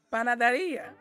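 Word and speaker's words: panadería: Panaderia.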